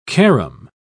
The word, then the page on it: carom: British English